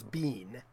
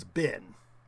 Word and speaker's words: been: It's B-R-E-E-C-H-E-S. It's breeches, not breeches. – Mm, right. – And the American long E, which in Canada they still pronounce it, I would hear it incorrectly, B-E-E-N. Dan Aykroyd always goes bean. We would pronounce it as bin.